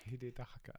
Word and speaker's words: Hidetaka: Hidetaka? What? – Hidetaka.